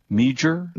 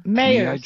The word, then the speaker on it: Meijer: What? M-E-I-J-E-R? Mueller? – Mayer. – Mueller? Is that how it's pronounced? Mayer's? – Yeah, yeah.